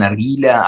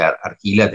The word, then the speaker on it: argila: En general se pronuncia medio como se dice, argila, te dicen todos y chao.